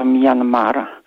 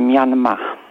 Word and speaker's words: Myanmar: A jeśli już chodzi panu redaktorowi o to miejsce, które się 30 lat temu nazywało Birma, to obecnie jest to Myanmar, albo wymawiane Myanmar.